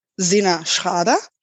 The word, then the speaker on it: SinnerSchrader: In German, it's pronounced Zina Schrader.